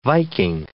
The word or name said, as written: Viking